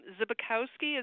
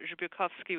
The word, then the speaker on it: Zbykowski: My last name is Zbykowski. It's very American, but Zbykowski would be more Slavic. Polish.